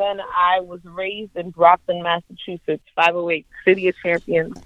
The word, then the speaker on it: Brockton: Like you know, the Brock Lesnar, which was a wrestler, and Brockton.